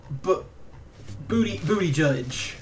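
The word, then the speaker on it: Buttigieg: And that is Mr. Pete Booty Judge. I'm sorry. That's his name. That's how he says it's pronounced. It's either Booty Judge or Booty Judge.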